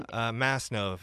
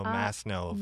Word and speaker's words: Masnov: Masnov. Hard S, long O. So Masnov.